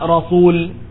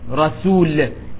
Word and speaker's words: رسول: It's not Rasul with a Sad, which is the common way we often pronounce it. It is not Rasul, Rasulullah, it's Rasulullah, Rasul, because it's a Seen, not a Sad.